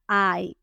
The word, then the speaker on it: eye: Entonces, ojo, hay obviamente ojos, eyes, eyes, se escribe e y -E, pero tiene una pronunciación bastante particular, lo siento, se pronuncia ay, como si lo estuvieran pellizcando a uno, ay, ay, ay, ay.